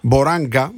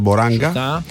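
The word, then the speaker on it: Boranga: Ο Λαμπέρτο Μποράνια. Μποράγκα, μάλλον, δεν είναι GN, είναι NG, άρα διαβάζεται Μποράγκα.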